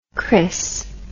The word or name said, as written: crios